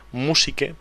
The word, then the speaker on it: Musique: Y además, de paso, sí también damos a conocer un portal de música principalmente francesa que se llama Musique, permitirme pronunciarlo así, digamos, Music. Sin hacerlo, Music Libre. Pero bueno, Musique-Libre, Musique con Q-U.